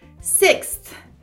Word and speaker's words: sixth: Porque tem esse som de x, x, x, sixth, sixth. É numa respirada só.